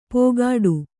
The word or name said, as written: ಪೋಗಾಡು